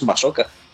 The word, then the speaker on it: Masuoka: Masuoka. Que sí, claro, si lo lees bien, como la U no se pronuncia, es Masuoka.